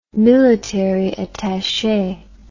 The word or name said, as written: military attache